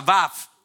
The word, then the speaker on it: Vav: Y esa palabra se pronuncia Vav, B corta, A, B corta.